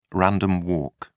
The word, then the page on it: random walk: British English